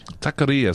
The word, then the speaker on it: Taqueria: But getting back to your new book, Taqueria, am I saying that right? – Taqueria. It's all about the Ria, they tell me.